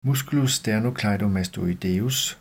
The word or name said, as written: m. sternocleidomastoideus